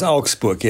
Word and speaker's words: Augsburg: And he said it while passing through a place will be familiar to you, which is, I think it's pronounced Augsburg. – Yes, it's pronounced Augsburg.